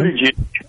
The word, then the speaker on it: Buttigieg: Buttigieg. – That nice job. I thought for sure he was going to do it. – Oh, man. – I did. I thought for... So, Buttigieg, Kamala Harris, your girl.